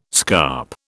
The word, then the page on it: scarp: British English